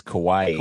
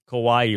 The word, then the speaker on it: Kauai: The island is Kauai. – Kauai. Rhymes with Hawaii.